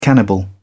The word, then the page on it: cannibal: British English